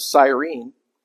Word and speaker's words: Cyrene: So this guy, Simon of Cyrene, as my wife correctly pronounced, was recruited.